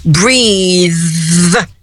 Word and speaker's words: breath: E o fôlego, breath, mudou tudo. É escrito parecido, só tem um Ezinho no final no verbo, mas mudou a pronúncia completamente. Agora, a fricativa interdental não vibra as cordas vocais. E o não é mais, o E já tem som agora de E mesmo.